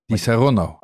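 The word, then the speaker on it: Disaronno: Dat moet je dan op zijn Italiaans uitspreken. Di Saronno.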